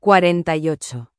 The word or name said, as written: 48